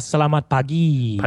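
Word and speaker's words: selamat pagi: Uh, is uh, Salamat Paji. Uh, I think that's how you say it. Uh, did I do that right? I hope I said close. Uh, salamat pagi. Pagi.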